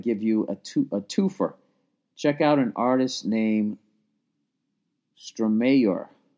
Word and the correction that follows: Stromae: That's how you say it, Stromayor. No. My God, I'm an idiot. Oh, my God, I'm a fucking idiot. It's Stromae.